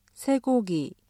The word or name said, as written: 쇠고기